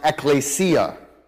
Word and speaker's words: ekklesia: When New Testament writers used this idea in Scripture, they almost always, at least in the New Testament, using the word Greek, used a word that's pronounced ekklesia.